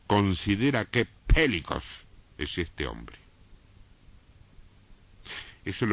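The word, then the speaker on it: pelikos: La palabra grande aquí es la palabra pélicos.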